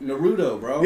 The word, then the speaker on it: Naruto: No, they don't even say it right. Naruto, bro.